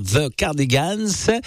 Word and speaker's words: The Cardigans: The, attention à la prononciation en anglais, c'est the, voilà, la langue entre les dents, the Cardigans, Eras, Rewind, et beaucoup plus récent, Lynn, dans ton téléphone sur Radio Vinci Autoroute, bien sûr, le téléphone au volant, on oublie.